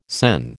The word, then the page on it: SEN: American English